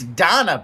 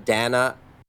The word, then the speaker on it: Dana: Dana. – I think it's Donna Bash or Dana Bach. It's Donna Bash. – Donna. It's spelled Dana. – It's spelled like Dana, but she pronounces it Donna.